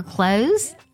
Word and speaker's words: clothes: Did you at least bring my clothes? 这句话当中呢可以听得到 clothes 衣服呢，它呢将词尾读作了 z 的音 clothes。